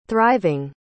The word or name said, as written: thriving